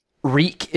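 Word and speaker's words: Rec: Reek is how we would say it if we were saying the word record.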